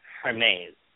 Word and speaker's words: Hermes: The boy's name is Hermes, with a silent H. And the daughter's name is Helena. – Hermes, like the store.